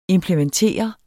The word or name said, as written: implementere